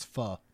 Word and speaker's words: pho: I think what you said was so funny because it came, and again, it's like a faux bowl. You know, it's a fake? – Ramen noodle. Huh? – You said faux. – Faux, yeah. Like actually, it's pho. It's pronounced pho.